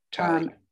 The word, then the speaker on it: Talia: Yes, his mother's name is, again, I'm sorry if I butcher this, Talia or Talia? – Talia.